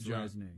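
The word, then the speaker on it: Rzeznik: Resnick. I mean, it's R-Z. We don't even get a vowel between. We got two consonants. Resnick? Is that how you say it? – Mm-hmm. I think it's Resnick. – John. – Resnick. – Here he is. – Yeah, R-Z-E-Z.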